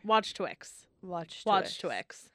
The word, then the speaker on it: Wachowski: So, John Wachowski. No, that's not how you say it. God damn it. I wrote it out phonetically. Watch Twix.